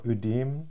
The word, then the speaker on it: Ödem: Also erstmal Ödem. – Ödem. – Die Betonung auf die erste Silbe.